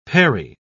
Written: perry

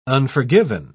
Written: unforgiven

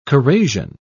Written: corrasion

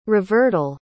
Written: revertal

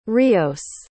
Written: rios

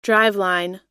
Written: driveline

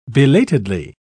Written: belatedly

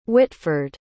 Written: whitford

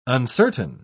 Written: uncertain

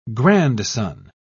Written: grandson

